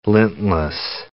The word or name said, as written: lintless